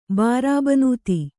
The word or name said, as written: ಬಾರಾಬನೋತಿ